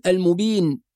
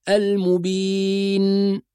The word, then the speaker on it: المبين: بعض القراء لما يقف على النون الساكنة يغلق الفم فيقول المبين، فيحول النون إلى ميم، هو نطق النون ولكن أغلق الفم، فلما أغلق الفم انطبقت الشفتان فتحولت النون إلى ميم، وهذا خطأ في الأداء. فلما ننطق النون الساكنة نحافظ على أن يبقى الفم مفتوحا حتى تظهر النون بصورة واضحة، المبين، هكذا المبين، وأنا فاتح الفم.